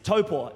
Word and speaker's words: Taupo: You probably call it Taupo, but the politically correct, or the actual, just the correct way to say it is Taupo, and like Taupo.